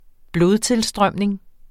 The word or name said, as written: blodtilstrømning